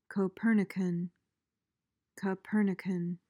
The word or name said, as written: copernican